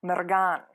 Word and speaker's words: Mergen: So I took Mergen gladly, but it's actually Mergan. You have to say it like that. Only that it should flow better than what I just did.